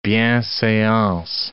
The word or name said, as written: bienseance